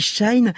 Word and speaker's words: Shein: Eh bien, Shein, alors certains prononcent aussi Shine, est une licorne à 100 milliards de dollars, 900 millions d'euros.